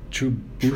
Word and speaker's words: True Booch: Can I, True Booch? – True Booch. – Why can't I get True Booch? – Say kombucha. Can you say kombucha? – So that's it. True Booch. It rhymes with the kombucha.